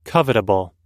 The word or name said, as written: covetable